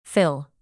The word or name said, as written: fill